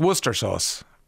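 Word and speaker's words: Worcestershire sauce: 51551 It's pronounced Worcester sauce. Why is it spelled Worcestershire, if it's pronounced Worcester sauce? I don't get that.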